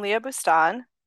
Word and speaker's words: Leah Boustan: Well, I'm Leah Boustan. that is how you say it.